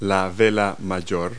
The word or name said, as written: mainsail